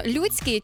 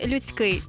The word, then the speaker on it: людський: Але як правильно його наголошувати, щоб було філологічно підковано і грамотно? Людський чи людський? Тут у нас, скажу тобі, повна свобода, тому що мовознавці офіційно дозволили подвійний наголос, тож можеш спокійно казати і людська робота, і людська робота, людський вчинок. Обидва варіанти є абсолютно правильні і зафіксовані в словниках.